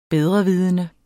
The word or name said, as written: bedrevidende